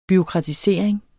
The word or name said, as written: bureaukratisering